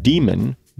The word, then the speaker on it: daemon: And it is pronounced demon, D with a long E, M, and then a schwa, which is an upside down E, N.